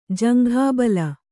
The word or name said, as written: ಜಂಘಾಬಲ